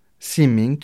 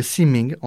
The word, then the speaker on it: simming: La natation, simming, qui se rapproche de l'anglais avec swimming, mais simming en suédois.